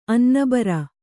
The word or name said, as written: ಅನ್ನಬರೆ